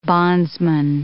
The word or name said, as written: bondsman